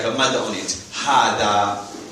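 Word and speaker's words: hada (هذا): Hada. Hada means... Hada means this. Technically, hada is pronounced like this. Ha and the. It's hada. It's as if there's a alif there.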